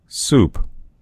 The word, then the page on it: soup: American English